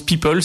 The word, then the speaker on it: PIPL: Elle s'appelle le PIPL, qui d'ailleurs se prononce People.